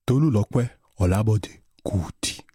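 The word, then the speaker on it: Tolulope Olabode Kuti: Tommy Cuti all'anagrafe, nome però un po' difficile da pronunciare. Come si pronuncia? – Tolulokwe Olabode Cuti.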